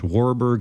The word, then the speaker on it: Warburg: We're not at human trials, but a new way of looking at cancer treatment that ultimately revolves around this Warburg effect, I said it correctly that time, and lactate's role in the metabolism.